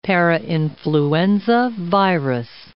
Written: parainfluenza virus

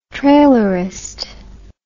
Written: trailerist

